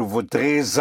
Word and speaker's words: voudrais: Je voudrais is spelled, voudrais is spelled V-O-U-D-R-A-I-S. So we have an S here at the end, a silent S, which is followed by a vowel of have.